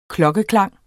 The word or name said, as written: klokkeklang